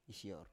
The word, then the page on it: isior: Biak